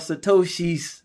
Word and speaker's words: Satoshis: So listen, we talking about Satoshi's, right? That's how you say it? – Yes, sir.